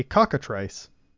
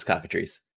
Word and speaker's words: cockatrice: The next one is called a cockatrice. – Oh, that's definitely a D&D monster. And you're pronouncing it wrong. It's cockatrice.